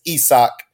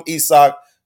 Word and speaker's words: Isaac: Isaac Bonga, we learned this week that the correct pronunciation, his mother pronounces his name as Isak. So that's his name. His mama called him Isak, so his name is Isak.